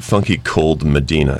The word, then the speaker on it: Funky Cold Medina: Well, this is the funky Comadina, if you hadn't figured that out. Did I say that right? Comadina? – Funky Cold Medina.